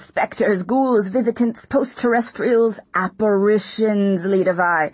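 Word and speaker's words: Lidewij: Behind it, I heard a stammering, reedy voice shout, Lidovai! – Until then, I'd pronounced his assistant's name like Lidowich.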